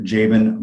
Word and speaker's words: Jaban: No cool names, just Dr. Jabin Moore. My name is spelled J-A-B-A-N for those out there, because I know it's a little different.